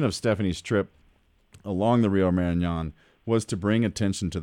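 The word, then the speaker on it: Marañón: Yeah, it's the Rio Marañon, and it's an amazing river.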